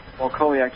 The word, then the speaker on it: Wachowiak: Is that pronounced Wachowiak, right? – Wachowiak, just like it is, yes.